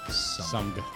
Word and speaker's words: Sumg: Sumg. With a hard G.